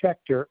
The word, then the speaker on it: Schechter: Yeah, it's Schechter, and I'll spell it for you. S-C-H-E-C-H-T-E-R.